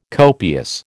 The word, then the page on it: copious: American English